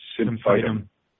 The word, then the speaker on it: Symphytum: Um, the genus name of comfrey, how do we pronounce this here? Symphytum. Mm-hmm. Yeah, is derived from the Greek uh, sympho to make grow to make grow together.